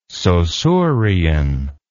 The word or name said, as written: Saussurean